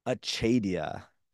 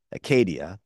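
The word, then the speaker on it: acedia: Achadia. It's coming from the Greek Acadia, but and you know, easily pronounce it in the Latin version, Acadia.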